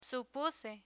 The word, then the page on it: supuse: Spanish